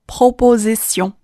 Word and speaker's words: proposition: 我们把它都连起来，po po z sion proposition。